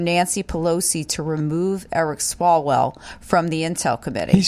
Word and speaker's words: Swalwell: Swalwell.